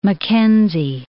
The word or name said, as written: Mackenzie